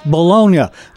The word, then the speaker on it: Bologna: Of course, purists might pronounce that Bologna, like the city from Italy from whence it came, only in the old country they don't even call it Bologna.